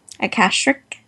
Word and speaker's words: Akashic: Oh, Akashic. Akashic. Okay, good.